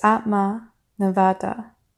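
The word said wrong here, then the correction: Atma Nivedana: Oops, I just said that wrong. My apologies. Atma Nivadana, which is self-surrender.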